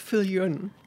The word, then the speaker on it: Viljoen: It's pronounced Viljoen. Viljoen. Afrikaans, straight from South Africa. Okay, but originally French? Originally French, yeah, Villon.